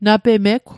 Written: napemegw

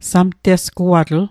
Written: samtesguatl

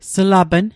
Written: slapn

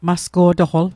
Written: masqo'toql

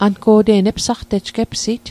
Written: epsit

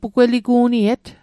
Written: pugweligu'niet

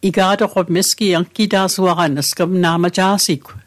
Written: angita'suaqan

